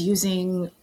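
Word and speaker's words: UUSI: I was using a deck called the Pagan Otherworlds deck by Usi.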